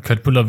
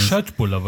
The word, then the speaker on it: Köttbullar: Köttbullar wird übrigens nicht Köttbullar ausgesprochen.